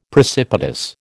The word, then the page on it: precipitous: American English